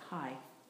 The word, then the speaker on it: Hayy: And the practice is Yahai Yahakayum. Yahai Yahakayum. Yahai. First, let me just pronounce it and say it. It's spelled H-A-Y-Y, but it's pronounced like hi.